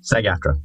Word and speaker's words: SAG-AFTRA: Is that how you say it? – SAG-AFTRA, yes.